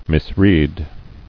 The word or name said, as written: Misread